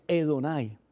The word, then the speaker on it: Adonai: Entonces, lo que hacen estos estudiosos es que hasta ese tiempo y después de ese tiempo también, se utilizaba el nombre Adonai, que se pronuncia Edonai, que quiere decir el más alto, el que está por encima de todo, al fin y al cabo, pues, Dios.